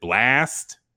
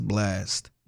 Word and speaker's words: BLXST: This name, I don't even know how the fuck to pronounce it. Blast? B-L-X-S-T. – I'm guessing it's Blast. – Yeah, it has to be.